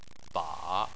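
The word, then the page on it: ba: tone 3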